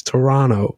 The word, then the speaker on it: Toronto: I pronounced it wrong the last time you were on the pod, and he messaged me going, "You pronounced Toronto wrong. It's Toronto." – Toronto, so, yeah, you're doing it right now too. – So is it with the silent T? – It's that Toronto.